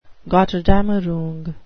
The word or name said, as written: gotterdammerung